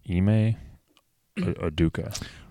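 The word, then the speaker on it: Ime Udoka: Ima Aduka? – Excuse me? – I think that's his name. Yeah, currently the head coach of the Boston Celtics. Ime Aduka.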